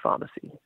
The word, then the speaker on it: Pharmercy: Pharmercy, but it's pronounced Pharmacy.